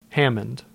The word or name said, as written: Hammond